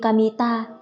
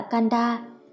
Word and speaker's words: Kamita: Tôi chỉ là một gã tên Kamita. Kamita đáp, viết bằng chữ Kami, thần và ta, cánh đồng, nhưng không đọc là Kanda, tôi sống ở gần đây đã lâu rồi.